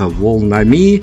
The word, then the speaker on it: Волнами: А, мы с белорусским таким прононсом позволяем себе произносить их название как «Волнами» а, от, э, от лагутенковского караванами, факсами и прочими вещами, которые по любви.